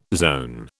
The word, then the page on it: zone: British English